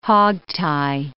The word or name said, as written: hog-tie